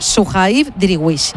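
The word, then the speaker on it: Suhaib Driouech: Y mientras van a lanzar el córner, que va a ser Mojica otra vez, os cuento que se llama Kuihav. A ver, se escribe Kuihav Driwech. Pero él dijo que se pronuncie Suhaib Driwech y que si era muy difícil que dijéramos Su.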